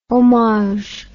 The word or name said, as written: hommage